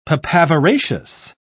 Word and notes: papaveraceous: British English

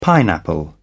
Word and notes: pineapple: British English

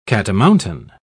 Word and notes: catamountain: British English

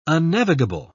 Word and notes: unnavigable: British English

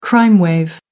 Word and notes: crime wave: American English

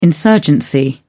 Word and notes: insurgency: British English

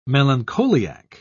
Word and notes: melancholiac: British English

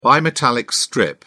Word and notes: bimetallic strip: British English

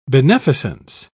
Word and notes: beneficence: British English

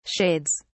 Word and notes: shades: British English

